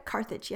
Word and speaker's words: Carthage: Carthage, yeah.